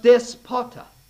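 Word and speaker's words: despota (δεσπότης): It's pronounced despota. Despot. Absolute ruler, my friends.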